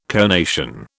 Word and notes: conation: British English

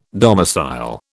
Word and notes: domicile: British English

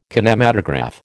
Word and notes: kinematograph: American English

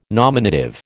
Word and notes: nominative: American English

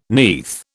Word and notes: neath: British English

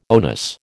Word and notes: onus: American English

